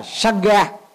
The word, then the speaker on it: Sangha: Ví dụ như tiếng Pali nó gọi là Sangha, thì người Việt Nam mình có thể phiên âm đọc ý dịch là Sangha, còn người Trung Quốc không thể đọc là Sangha được mà phải là tăng già.